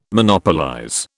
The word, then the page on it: Monopolize: British English